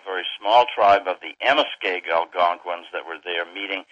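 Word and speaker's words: Amoskeag: Amaskegue Presbyterian Church, and if you could correct me if I've mispronounced that. – Yeah, you came as close as anyone ever has without hearing it first. It's Amaskegue. It's an Indian name, and it comes Indians there in that part of New England.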